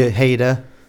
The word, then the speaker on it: Ojeda: Richard Ojeda. Again, I'm not sure how he pronounces his last name. It's O-J-E-D-A. – Probably Ojeda. – Ojeda? Right. Yeah, there you go.